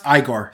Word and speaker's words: Igor: Its name is Igor. Pronounced Igor. Sorry.